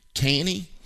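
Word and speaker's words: Tannie: And let's talk to Tanny. Tanny, did I get your name right? – Yes, hi. That's T-A-N-N-I-E.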